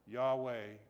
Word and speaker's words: Yahweh: Yahweh is the way that I pronounce it.